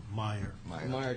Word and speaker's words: Meijer: But what about the Mayer? I'm not sure I'm pronouncing it. M-E-I-J-E-R case. – Meyer case.